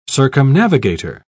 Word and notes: circumnavigator: British English

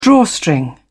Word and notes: drawstring: British English

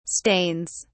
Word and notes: stains: British English

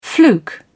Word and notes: fluke: British English

